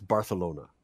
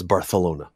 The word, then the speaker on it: Barcelona: A celebrity Ben story of the day offering comes to us from Barcelona, which is the proper way to say it. Not Barcelona, it's Barcelona. Just for those wondering, and my wife who always mocks me for that, but that's how you say it, I'm told. I don't speak Spanish, but I'm told it's Barcelona.